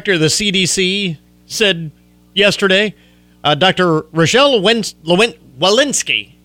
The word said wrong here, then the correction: Walensky: That I pronounced it, Dr. Rochelle Walensky.